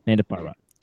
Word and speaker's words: Nanda Parbat: Did I say that right? Nanda Parbat? I'm going to say yep. Nanda Parbat. – No, you're good. – Nanda Parbat.